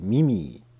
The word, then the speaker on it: MI3E: ミミーっていうポータルサイトなんですけどね、 MI3E3 は数字の 3 ね、 MI3E と書いてミミーっていう風な発音の仕方をするらしいんですけれども、